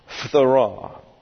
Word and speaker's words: phthora: That's P-H-T-H-O-R-A, phthora. And usually in English, we accent the second syllable going back from the end. So phthora would be a lot easier for us to say.